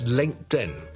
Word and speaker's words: LinkedIn: The second one is LinkedIn. LinkedIn. It comes from linked, L-I-N-K-E-D, linked. 有个 T 音在这里，其实 L-I-N-K-E-D 的 E-D 是个 T-M，那扔埋个 N 就会读成 LinkedIn OK，就不是 LinkedIn。那第三个就是 WhatsApp，不是 WhatApps， but WhatsApp 扔埋的 WhatTS 扔埋个 App 就变成 WhatsApp。所以讲到最后一次 Uber LinkedIn and WhatsApp。